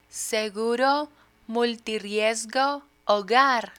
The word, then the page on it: seguro multirriesgo hogar: spanish